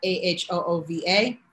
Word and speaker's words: Ahuvah: If you're on Twitter, I am at AHOOVA phonetically spelled Ahuva.